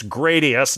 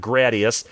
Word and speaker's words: Gradius: It's Gradius, not Gradius. There's only two people in the entire world, maybe three, that I know that pronounce it Gradius.